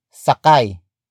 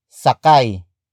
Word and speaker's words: sakay: Sakyan comes from the root word sakay. Sakay. Sakay, which is spelled as S-A-K-A-Y, meaning a passenger.